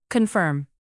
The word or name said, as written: confirm